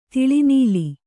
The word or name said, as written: ತಲೆನಿಲ್ಲು